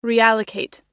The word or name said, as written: reallocate